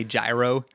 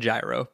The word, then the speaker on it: gyro: But yeah, we always said gyro, but it's gyro.